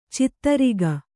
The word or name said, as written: ಛತ್ರಕ